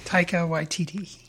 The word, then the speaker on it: Taika Waititi: Taika Waititi. – Taika Waititi.